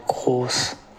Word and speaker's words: Gross: Yeah, so that would be pronounced Pascal Gross in German.